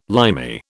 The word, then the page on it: Limey: British English